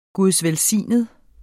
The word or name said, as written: gudsvelsignet